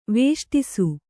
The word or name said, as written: ವಿಷ್ಟಿಸು